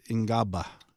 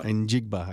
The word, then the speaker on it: Njigba: 23, Jackson Smith Ngaba. – Njigba.